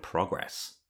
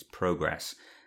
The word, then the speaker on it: Progress: And we are talking about Voyager's Heroes and Demons, TNG's Too Short a Season, and DS9's Progress, which, well, Americans would call it Progress, but it's Progress.